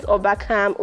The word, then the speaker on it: Obakam: And mine is Obakam, OBAKAM_.